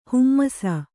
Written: ಹೇಮಿಸು

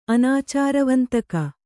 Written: ಅನಾಚಾರವಂತಕ

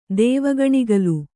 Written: ದೇವಗಣಿಗಲು

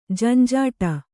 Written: ಝಂಝಾಟ

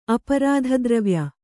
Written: ಅಪರಾಧದ್ರವ್ಯ

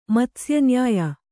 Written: ಮಾತ್ಸ್ಯನ್ಯಾಯ

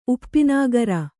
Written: ಉಪ್ಪಿನಾಗರ

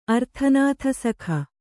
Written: ಅರ್ಥನಾಥಸಖ

